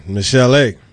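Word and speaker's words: Michel'le: Michelle. – Man, listen. – Is that right? – Listen, Michelle.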